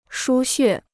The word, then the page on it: 输血: shū xuè